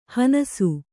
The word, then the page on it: ಹನಸು: hanasu